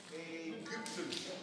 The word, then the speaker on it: Ägypten: Zo moet ik het uitspreken. Ägypten.